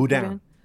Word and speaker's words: Boudin: Boudin. Yeah, like Boudin Blanc.